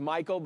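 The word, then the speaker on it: Micheal: My name is Michael, but it's spelled with an E-A-L. So everyone calls me Michelle.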